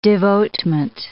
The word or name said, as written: devotement